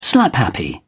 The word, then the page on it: slaphappy: British English